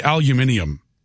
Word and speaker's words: aluminium: Another, he probably also pronounces aluminum wrong. – Aluminum. – Yeah. – Aluminum. – The British say aluminium.